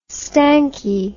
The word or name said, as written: stanky